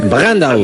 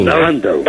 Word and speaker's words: Brandau: ¿Cómo se pronuncia, Brandau en alemán? – Brandau. – Brandau. – Exacto.